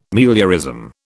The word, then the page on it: meliorism: British English